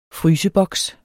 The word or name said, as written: fryseboks